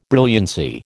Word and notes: brilliancy: American English

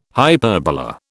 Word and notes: hyperbola: British English

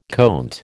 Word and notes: conte: American English

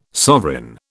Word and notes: sovereign: British English

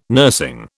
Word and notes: nursing: British English

